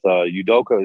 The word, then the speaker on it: Udoka: He's coaching with Udoka.